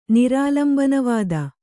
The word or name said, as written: ನಿರಾಲಂಬನವಾದ